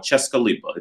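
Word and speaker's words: Česká Lípa: It's like a small town called Cheskalipa. It's spelled CESKA LIPA.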